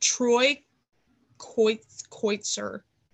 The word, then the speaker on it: Kotsur: Troy Kotsur.